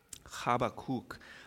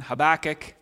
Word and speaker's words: Habakkuk: I am told that if we were ancient Israelites, that is how we would say that. Habakkuk. I won't say it like that anymore. I'll stick with my pronunciation, Habakkuk, and you can call it what you like.